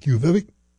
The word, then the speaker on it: Quviviq: Quviviq? And if you look at how it's spelled, you'd never think that's how it's pronounced. Q-U-V-I-D-Q-U-U-U.